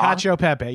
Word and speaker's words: cacio e pepe: I feel like cacio e pepe, I'm sure I'm saying that so wrong. – Cacio pepe. You're saying it right.